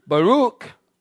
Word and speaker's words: barak: B-A-R-A-K. It's used in Judges 5, 12, Psalm 72, 12 through 15. Baruch, or Barak, some people pronounce it, speaks of silent praise from the heart.